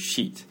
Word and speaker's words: sheet: 예, 안서 키 또는 답지 이게 종이로 되어 있다는 것을 강조하고 싶으면 answer sheet. – Answer sheet. – 종이 한 그 장 sheet이라고 하죠. – 발음 조심해야겠네. – 예, 그러니까 발음 sheet는 조금 길게 장음으로. 근데 안전한 게 앞에 answer 붙이면은 사람들이 헷갈리지 않겠죠? – 네.